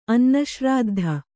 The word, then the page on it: ಅನ್ನಶ್ರಾದ್ಧ: annaśrāddha